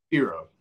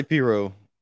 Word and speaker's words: pirogue: But you can't pronounce the G too heavily either. So the way I was thinking about it is you have to say it fast enough to where nobody really knows how you pronounce it. – If you, they're not sure if you said the G or not. – Right. Just Piro. – So I say Piro. I don't say the G, right.